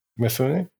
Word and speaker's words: Mifune: Toshiro Mifune. – Mifune.